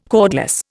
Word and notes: cordless: Indian

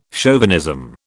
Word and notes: chauvinism: British English

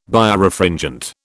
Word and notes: Birefringent: British English

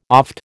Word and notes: oft: American English

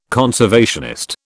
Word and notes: conservationist: British English

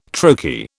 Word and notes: trochee: British English

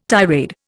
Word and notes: tirade: Indian

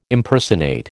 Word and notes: Impersonate: American English

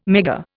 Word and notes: Megger: Indian

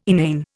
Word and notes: inane: Indian

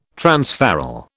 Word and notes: transferral: British English

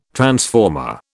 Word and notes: transformer: British English

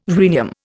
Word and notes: rhenium: Indian

